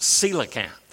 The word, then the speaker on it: coelacanth: I know the word looks like coelacanth, but it's pronounced coelacanth.